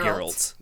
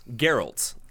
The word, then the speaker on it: Geralt: Geralt. Geralt.